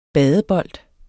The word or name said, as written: badebold